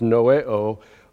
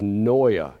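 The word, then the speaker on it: noeo (νοέω): The Greek is on top, and it's pronounced noeo. Underneath is the Americanized, Latinized pronunciation, which is noia.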